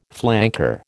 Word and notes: flanker: American English

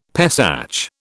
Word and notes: Pesach: British English

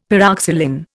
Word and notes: pyroxylin: Indian

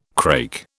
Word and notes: crake: British English

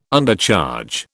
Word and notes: Undercharge: British English

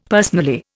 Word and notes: personally: Indian

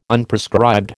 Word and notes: Unprescribed: American English

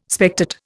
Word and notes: spectate: Indian

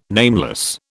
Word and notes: nameless: British English

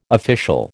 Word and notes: official: American English

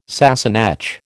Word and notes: Sassenach: American English

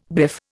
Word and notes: biff: Indian

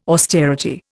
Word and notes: austerity: Indian

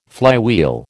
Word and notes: flywheel: American English

